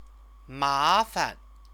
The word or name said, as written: mafan